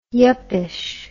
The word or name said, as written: yuppyish